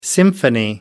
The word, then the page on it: symphony: British English